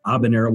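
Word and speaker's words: Habanero: Abanero with an H, H A B A N E R O N E R O. You know, it's the H is silent.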